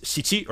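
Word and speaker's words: shichi (しち): Well, so 1 + 6 = 7, which is pronounced shichi, or can be pronounced shichi, and the shi part means death, or can mean death.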